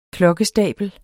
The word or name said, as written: klokkestabel